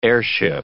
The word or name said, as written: airship